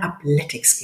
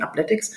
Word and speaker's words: upletics: Und zwar Jana Hartmann und Markus Hoselmann also von der Athletics GmbH. Habe ich das erstmal richtig ausgesprochen, ihr beiden? Athletics? – Soweit so gut.